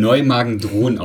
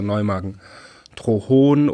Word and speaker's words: Neumagen-Dhron: Du bist in Neumagen-Dhron aufgewachsen.